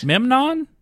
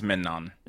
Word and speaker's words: Memnon: And the one who has done all this is the current Scorpion King named Agamemnon? – You racist. Memnon? Say it right. – Memnon? – I think it's Memnon.